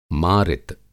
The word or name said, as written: मारित